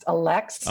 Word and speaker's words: Allexe: My name is pronounced Alex. – Alex, I'm sorry. – And no, it's okay because it's spelt A-L-L-E-X-E. So most people do want to pronounce the E at the end of it or pronounce it in a very different way. So it usually is the fourth try that people get it right.